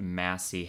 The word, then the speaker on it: Massie: The house sits across the street from, I believe you pronounce it Massey Heritage Center, mm-hmm. – M-A-S-S-I-E.